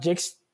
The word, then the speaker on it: Jakes: Thomas Jex, T.D. Ah, euh, C'est TDJX, J-A-K-E-S, euh, e parce qu'il y a des gens qui ne sont pas anglophones, je l'ai prononcé en anglophone.